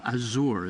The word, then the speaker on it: Azure: You have a signature resort called Azure. Is that the way you pronounce it? – Yes.